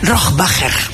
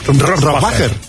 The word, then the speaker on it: Rohrbacher: Rohrbacher. – Rohrbacher.